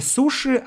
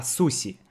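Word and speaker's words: суси (寿司): Причем в Японии говорят суси. Я так слышал, да, я не знаю японского, но говорят, что суси. Не суши, а суси.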